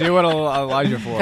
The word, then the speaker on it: Chiwetel Ejiofor: because Chibisha, or whatever his name, however you pronounce it, Chiwetel Ejiofor.